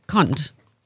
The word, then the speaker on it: Connacht: The Sharks 12-13, and is it Connacht that we say? How do we pronounce this sports team? C-O-N-N-A-C-H-T. – Connacht.